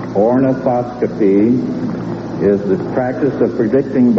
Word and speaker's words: ornithoscopy: Ornithoscopy.